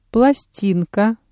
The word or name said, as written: пластинка